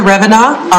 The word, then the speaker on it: Revenaugh: My name is Linda Revenaugh, R-E-V-E-N-A-U-G-H.